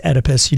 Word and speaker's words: Oedipus: It's Oedipus. You didn't Oedipus. – Pronounce the O.